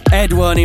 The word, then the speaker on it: EdOne: EdOne, in fact.